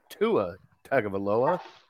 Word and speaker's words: Tagovailoa: And then you got Derek Carr, Jared Goff, Russell Wilson, Tua Tagovailoa. Did I pronounce that right? – You got it.